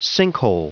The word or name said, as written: sinkhole